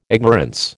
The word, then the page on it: ignorance: American English